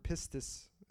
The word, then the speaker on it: pistis: In the Greek, it's the word pistis, is how you say it.